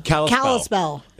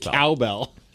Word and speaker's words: Kalispell: Did you say Cowbell, Montana? – Kalispell. – Is that how it's pronounced? – Kalispell. – I thought you said Cowbell.